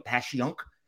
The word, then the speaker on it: Passyunk: and then Passyunk Ave. I hope I get that right. Passyunk.